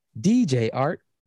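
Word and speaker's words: DJ Artt: DJ Art, with two T's for a double dose of ting ting. The D is silent, so it's just Jart.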